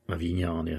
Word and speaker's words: Avigliana: Although I do have a little bit of information about this castle of Avignana, or it's spelled Avigliana, but Avignana I think is how the Italians would say it.